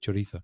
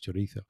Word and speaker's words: chorizo: Because a Latin American would pronounce the Z as an S, chorizo. – Mm-hmm. – And Spanish people, most Spanish people, apart from in Andalusia, I suppose, would say chorizo. Mm. And English people pronounce the Z, chorizo, chorizo. – Yes. – With a Z sound, which is not correct.